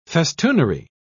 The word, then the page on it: festoonery: British English